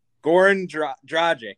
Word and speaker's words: Goran Dragic: Goran Dragic. – Dragic. – Goran Dragic. – Dragic. – Goran Dragic.